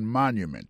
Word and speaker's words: monument: Pour mot monument, il tombé sur premier syllabe là. The Washington Monument. Monument, c'est comme ça on dit monument en anglais.